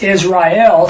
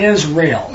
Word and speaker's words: Israel: So it's Israel, three syllables, not Israel.